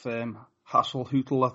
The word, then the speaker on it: Hasenhüttl: Yeah, and you mentioned there about your new manager, Ralph Hasenhüttl, I think you pronounce it.